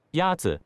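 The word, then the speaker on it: Yatsu (鸭子): Is it Yatsu? – Yatsu is pronounced with a first tone. In Mandarin, there are four tones, which are essential to distinguish the meaning of words. The first tone is a high-level tone.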